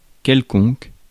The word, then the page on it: quelconque: [kɛl.kɔ̃k]; France (Paris)